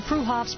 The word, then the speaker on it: Freehoff's: Spelled Freehoff's, pronounced Freehoff's, 33rd and Arapahoe in Boulder.